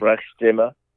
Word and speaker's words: Sprechstimme: Sprechstimme. – Yes. Yeah, see, I would have totally mispronounced that. So, You have to sort of roll your R a little bit.